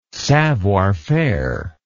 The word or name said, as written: savoir faire